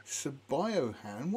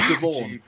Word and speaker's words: Siobhan: Siobhan, because it's spelled Sabiohan. And the first time I saw it, I was going, Sabiohan? What the hell's name is that? Sabiohan? And somebody said, it's Siobhan.